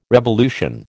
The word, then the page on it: revolution: American English